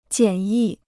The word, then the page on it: 简易: jiǎn yì